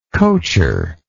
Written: coacher

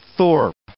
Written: thorp

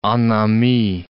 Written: en ami

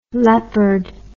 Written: Leppard

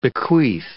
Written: bequeath

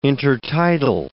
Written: intertidal

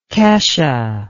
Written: kesha

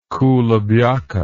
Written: kulebyaka

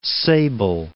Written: Sable, Cape

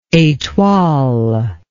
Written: etoile

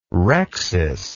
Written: rhexis

